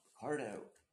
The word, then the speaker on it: Pichardo: And a shout out to Olivia Pichardo. Is that how you say it? – Or Pichardo or Picardo? – Picardo. She is the first, she made history.